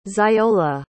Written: xiola

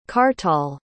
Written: kartal

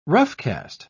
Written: roughcast

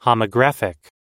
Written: homographic

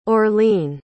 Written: orlene

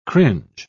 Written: cringe

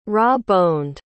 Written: raw-boned

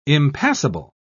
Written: impassable

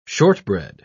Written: shortbread

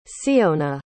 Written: ceona

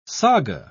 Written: saga

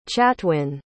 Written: chatwin

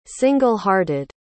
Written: single-hearted

